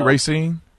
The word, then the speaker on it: Racine: I'm saying it right. It's Racine? – Yes, it's Racine, Wisconsin.